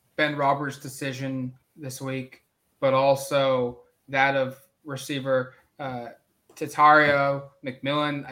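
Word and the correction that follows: Tetairoa: I probably butchered his first name. I've still not been able to pronounce it correctly. – Tetaroa. – Tetaroa, that's correct.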